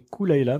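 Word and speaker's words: Caol Ila: Kaolila. – Okay. – Alors, qu'on prononce a priori Kulaila, mais ça fait bizarre.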